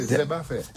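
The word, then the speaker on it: Zeb à fè: Zeb, comme herbe, à fait. – Zeb. – Parce qu'il est chargé en fer aussi. – À Zeb à fer. – Ouais. On prononce pas les R ici.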